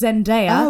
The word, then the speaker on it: Zendaya: I pronounce it Zendaya. – I think it's Zendaya. – It's definitely pronounced Zendaya.